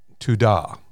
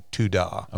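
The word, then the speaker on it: todah (תודה): But it's todah. – Okay. – It's like T-O-D-A-W, todah.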